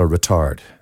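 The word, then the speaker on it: ritard: And if you slow that down, it's called a retard. And that's not spelled like a. That's like the Italian, it's a R-I-T-A-R-D.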